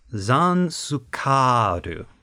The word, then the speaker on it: Zanscare: It like looked like Zanikar or something along those lines. I don't know. – It's spelled, we're doing this again, it's spelled like Zanscare. Z-A-N-S-C-A-R-E. It is pronounced, in the Japanese, Zansukaru.